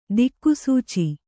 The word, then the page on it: ದಿಕ್ಸೂಚಿ: diksūci